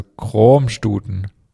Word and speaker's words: Kroamstuten: Dann gibt's Kroamstuten, wird mit einem langen, offenen O ausgesprochen. Das A ist stumm, ähnlich wie das O in kommen.